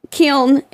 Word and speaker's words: kiln: Kiln is the oven that we bake the pieces in. Am I saying that right? – Yeah. – Kiln.